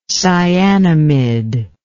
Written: cyanamide

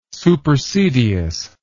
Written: supersedeas

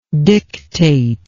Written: dictatingly

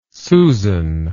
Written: susan

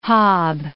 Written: hob

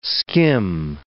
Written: skim

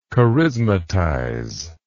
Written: charismatize

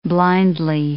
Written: blindly

